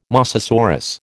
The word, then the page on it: mosasaurus: American English